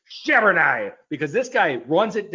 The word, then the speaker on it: Charbonnet: Did I say it right? – Hey, you got to get with the French inner Woj. Charbonnet, Woj. Zach Charbonnet. Charbonnet, Woj.